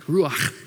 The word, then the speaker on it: ruach: The word for spirit is the word ruach. Say it like that, ruach.